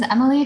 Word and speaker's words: Emilie: Yeah, so mine is Emily Fantuz, and my name is spelled Emilie.